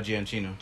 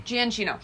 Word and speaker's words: Giacchino: Michael Giacchino? – Oh, Giacchino. – Giacchino.